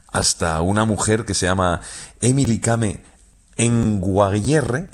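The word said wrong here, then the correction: Kngwarreye: lo he dicho mal. – Emily Kame. – Con Guarreye. – Emily Kame con Guarreye, sí, sí.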